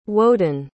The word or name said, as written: woden